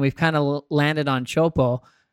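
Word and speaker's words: Teahupo'o: We're going like, okay, help us pronounce, you know, what we first heard as Chopu. And then we started saying, no, it's an O, Chopo.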